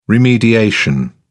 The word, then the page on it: remediation: British English